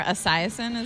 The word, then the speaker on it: Esiason: Boomer Esiason is his name? – Yeah.